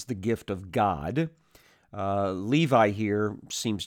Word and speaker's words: Levi: As he passed by, he saw Lawi, or Levi is the way we typically pronounce this in English now, the son of Alphaeus, sitting at the tax booth.